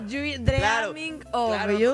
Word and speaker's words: Dreaming of You: Dime si lo pronuncio bien porque tú le sabes. Dream Me of You. – Ay, José, ¿sí? ¿No? Obvio no. – Dreaming. – Claro. Dreaming of You.